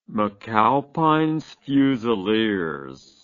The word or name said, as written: mcalpines fusiliers